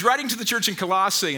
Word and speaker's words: Colossae: Paul was writing to the church in Colossae. Sarah, how do I say that? Thank you, Colossae.